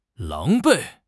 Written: 狼狈

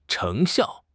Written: 成效

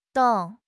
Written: 栋

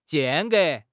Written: 间隔

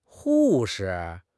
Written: hu4 shi5

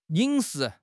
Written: yin3 shi2

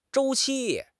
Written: 周期

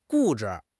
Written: 固执

